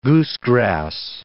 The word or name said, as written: goosegrass